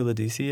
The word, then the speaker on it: Ledisi: And Ledisi, do you know who Ledisi is? – Mm-mm. – She's like a neo-soul. – Oh, Ledisi.